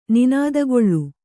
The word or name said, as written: ನಿನಾದಗೊಳ್ಳು